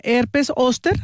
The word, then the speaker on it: herpes zóster: La herpes zóster, ¿así se pronuncia bien? – Herpes zóster, correcto.